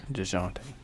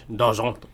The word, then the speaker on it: Dejounte: And then on top of that, how do you say his name? DeJounte? – DeJounte. – Murray. That's French. – DeJounte.